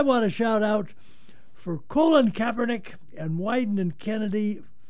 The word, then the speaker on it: Colin: Just under the banner of pronunciation police, he pronounces it Colin. – Colin, okay. Colin right. Kaepernick.